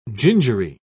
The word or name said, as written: gingery